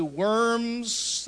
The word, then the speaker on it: Worms: Sometimes you look at that diet of, and it's spelled with a W, worms.